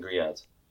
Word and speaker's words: griots: Yeah grits and grulots. Am I saying that right? close. Though. Grits and griots.